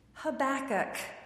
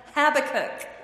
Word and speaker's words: Habakkuk: The name of the prophet is Habakkuk. But for the life of me, I always, always, always want to call him Habakkuk.